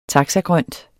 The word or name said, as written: taxagrønt